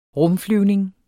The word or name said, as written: rumflyvning